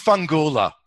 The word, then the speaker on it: Fungula: Am I pronouncing it right? – You are. – Okay. – It's Fungula, which is Fungula, actually... – Fungula, okay.